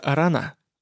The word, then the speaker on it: Арана: Река называется Арана, с ударением на последнем слоге.